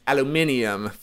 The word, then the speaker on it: aluminium: But in American and Canadian English, you're talking aluminum. For everyone else, it's aluminium.